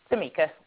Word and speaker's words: Tamika: Tamika. – Tamika. Is it Tamika or is it Tamika? – T-A. – Tamika. – Anisha. – Okay, Tamika. T-A-M-I-K-A.